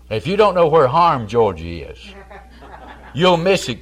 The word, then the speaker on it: Hiram: All natives around Hiram, Georgia, pronounce it Harm.